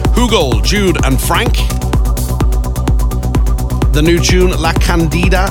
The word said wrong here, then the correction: La Candela: is that how it's pronounced? La Candela, La Candela.